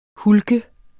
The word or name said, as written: hulk